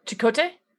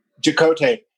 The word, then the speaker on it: Chakotay: Jacoy, what's his name? Her first officer. – Oh, Chakotay. – Chakotay.